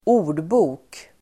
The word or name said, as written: ordbok